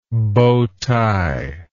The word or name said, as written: bow tie